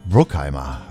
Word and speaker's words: Bruckheimer: Bruckheimer, ja.